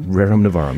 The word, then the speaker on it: Rerum Novarum: Did I pronounce it right this time? – Rerum Novarum, yes.